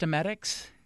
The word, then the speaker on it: emetics: Emetics, I guess. Is that how it's pronounced? Emetics? – Yes.